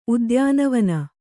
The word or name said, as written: ಉದ್ಯಾನವನ